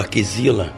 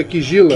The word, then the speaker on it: Kizila: se vocês querem comparar o tabu à Kizila, ou à Kizila, como queira que fale... – A pronúncia certa em banto é Kizila.